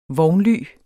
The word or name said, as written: vognly